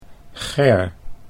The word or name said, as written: Ger